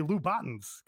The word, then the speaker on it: Louboutin: So I know how to say the French pronunciation. I'm saying Americans. You know how we were saying like It's Cairo, Illinois, because Cairo. They say Cairo, Illinois. – Right. – So it's Cairo, Egypt. That's what I'm saying. Americans say Louboutins because they don't know French.